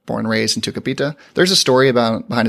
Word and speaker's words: Tucupita: Playing second base for the Fort Wayne Tin Caps, Tucupita Marcano. – Tucupita. I just wanted to say that again. – Wade's learned how to say his name properly now and just loves saying his name.